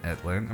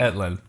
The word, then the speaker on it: Ettlin: Alexa is a pianist, composer, producer, and instructor, and the co-founder of Spaced Out Studios alongside Sean Etlin. Am I saying his name right? – Nailed it. Amazing. – Etlin, yeah.